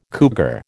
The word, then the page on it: cougar: American English